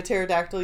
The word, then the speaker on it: pterodactyl: I said, do you know how to spell pterodactyl? And he looked at me for a second, and then he went, because the P is silent.